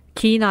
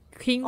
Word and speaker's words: quinoa: Quina.